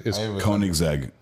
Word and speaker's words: Koenigsegg: It kind of looks like the Koenigsegg. Is that how you pronounce it? – Koenigsegg.